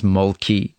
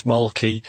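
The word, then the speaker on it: Mölkky: We use Mulkey. Most of the people we play against use Mulkey, but the Finns have a very unusual pronunciation, which, please forgive me, I'll try it. It's Mulke. So I think that's, something a bit more like that. But to us, it's Mulkey.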